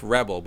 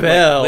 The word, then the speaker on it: Rebelle: It looked like it said Nerf Rebel, but it was spelled REBELLE, like rebel, rebel, but for women, like Belle. – Rebel.